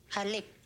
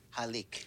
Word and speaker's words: halik: So, how do you say kiss in Tagalog? – Halik. – Halik.